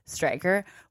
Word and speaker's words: Streicher: His last name is spelt S-T-R-E-I-C-H-E-R, but I went to his blog and he said it's pronounced Striker, which is a really cool name.